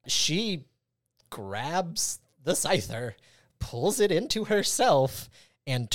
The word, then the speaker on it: Scyther: And it just so happens that there is a wild Comantis. – Scyther. You mispronounced Scyther.